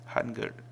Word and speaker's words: Hangul: C'est juste le mot qu'on vous a dit au début du cours, le Hangul. – Hangul. – Hangul. – Hangul, voilà. L'alphabet coréen. – Voilà, H-A-N-G-E-L. Voilà. – Vous remarquerez que là, le ni, il se prononce en E, en fait.